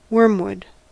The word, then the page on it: wormwood: American English